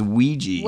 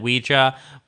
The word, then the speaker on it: Ouija: You know, yeah, it's spelled O-U-I-J-A, which is... – Well, it's French Ouija, yes. – Yes, Ouija. – And German Ja. – Yeah. – Yes. So it is the yes board.